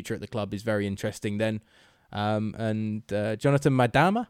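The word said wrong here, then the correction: Maidana: I think I'm saying that right. – Madana.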